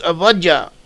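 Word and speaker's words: Avadja (עובדיה): In the name of Abijah in modern Hebrew, it's pronounced as Avadja.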